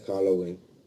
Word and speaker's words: Halloween: Feliz Howelin. ¿Cómo se dice? – Feliz Halloween. – Feliz Howelin. Grupo, soy Vanessa. – Halloween. Halloween. Y se escribe con J-A-L-L-O-W-I-N. Halloween. Ah, lo puede escribir W-Y-N, pero todos lo escriben con I latina.